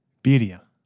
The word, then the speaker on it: Birria: Birria.